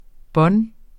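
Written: bånd

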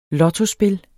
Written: lottospil

